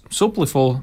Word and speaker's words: Supliful: un tad atkal paralēli darbam sāki Supliful, vai pat tā es pareizi izrunāju. – Jā, viss kārtībā, viss okay. Pareizi.